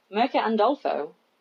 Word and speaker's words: Mirka Andolfo: Uh, Mirka Andolfo.